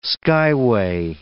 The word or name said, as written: skyway